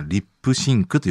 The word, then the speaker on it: Lipps Inc: 発音的にはリップシンクと読むらしいですね。